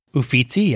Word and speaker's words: Uffizi: Of course, you've got to go to the Uffizi. Did I say that right? – Uffizi, yes. Uffizi. You did good.